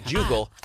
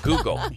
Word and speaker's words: Google: That's a hard G, not Jugal. Google.